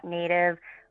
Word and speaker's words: NATV: It's just at Native Restaurant. It's going to be a black logo. It's spelled N-A-T-V with a line over the A to make it long.